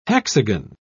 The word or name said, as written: hexagon